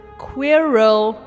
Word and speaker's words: Quirrell: Du hast es falsch gesagt. Es ist Quirrell.